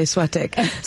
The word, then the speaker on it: Soitec: I pronounced it Soitec earlier, which is really wrong given that I'm French, so I should say Soitec.